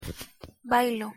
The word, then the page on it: bailo: Spanish